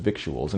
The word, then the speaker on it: victuals: V-I-C-T-U-A-L Виктуал. Продовольствие, припасы, провизия, питание. Чаще всего слово можно наблюдать во множественном числе – а теперь окунемся в произношенческое безумие. А ну, попробуйте без словаря прочитать слово. Victual. Носители с вами согласны. I get $25 a month with victuals and clothing, with victuals, victuals, victuals.